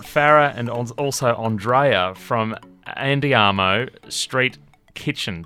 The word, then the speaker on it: Andiamo Street Kitchen: Did I say that right, Farrah? – Yes, you sure did. You should get Andrea to say it. – Say it in the Italian flair, Andrea. – It's Andiamo Street Kitchen.